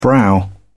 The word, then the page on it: brow: British English